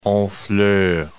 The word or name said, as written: Honfleur